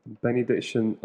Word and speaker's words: Benny Diction: Uh, Benny Diction underscore UK at Twitter and Instagram I'm Benny underscore Diction. And it's spelt like the name Benny and the word Diction.